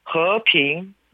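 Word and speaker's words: 허핑 (和平): 중국어로는 허핑 라고 얘기하면 됩니다. – 허핑. – 네. 거꾸로 얘기하는 거죠. 화평 이렇게 해서 허핑.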